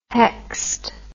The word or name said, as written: hoechst